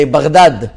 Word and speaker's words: Baghdad: Now, if you speak to the Iraqis, they'll say Baghdad. That's how they, that's how, that's how they say it.